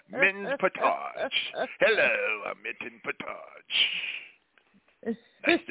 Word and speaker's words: Portage: Portage. – I'm sorry? – Portage. Portage. – How do you spell it? How do you spell it? – P-O-R-T-A-G-E.